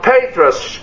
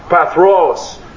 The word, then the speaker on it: Pathros: Now this is a natural prophecy from Patras, Cush, Elam, Shinar, Hamath, and from the islands of the sea. They should be pronounced. In the Hebrew it would be Pathros, Cush, Elam, Hamath, Shinar.